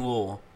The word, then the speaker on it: Louisville: And if you say it correctly, it's Louisville, so King Louis is who that's named after, but it's really Louisville.